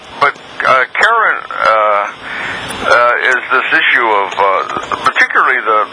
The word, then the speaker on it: Charon: I pronounce it Karen, and I don't remember who told me that, because my first teacher pronounced it Sharon.